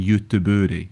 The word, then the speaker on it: Göteborg: There was a stage, I'm sure, when IFK Gothenburg were in the Champions League many moons ago, when one commentator used to always say Göteborg, which apparently is the Swedish pronunciation, although I can't vouch for that.